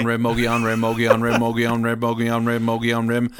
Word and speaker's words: Mogollon: This was a tricky word to pronounce because it's spelled Mogollon. So when I first saw it, I was like, Mogollon? – Mongolian? – No, it's like Mogollon. What the fuck's Mogollon?